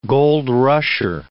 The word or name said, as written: gold rusher